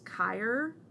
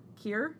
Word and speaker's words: Keir: And then the other is, I'm not sure how to pronounce his first name, if it's Kier, K-E-I-R, Gilchrist, who is... Kier? – Yeah, I think so.